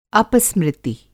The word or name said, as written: अपस्मृति